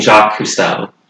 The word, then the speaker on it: Jacques Cousteau: She seems to be trying to pronounce the name Jacques Cousteau. That's the name. – Can you spell it? – J-A-C-Q-U-E-S.